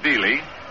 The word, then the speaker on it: Veale: Bob Vealey spells his name V-E-A-L-E and pronounces it Vealey.